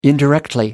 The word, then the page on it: indirectly: British English